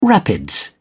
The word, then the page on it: rapids: British English